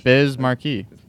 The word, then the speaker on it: Biz Markie: Am I saying it wrong? – No, you're saying it right. I think it's just funny to like say Markie. – Did you see my name up on the Biz Markie? – Yeah, exactly. You'll know it. You'll know it. – Say it right, because I'm saying it.